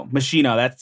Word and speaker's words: Moschino: So Sims 4, Moschino. How do you pronounce that? – I actually don't know. – Oh, I'm not wealthy enough to pronounce. Is it Moschino? – Moschino. That sounds better.